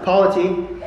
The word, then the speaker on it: polity: Uh, polity, and that's how you pronounce it polity, how a church is governed.